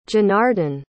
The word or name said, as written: Janardan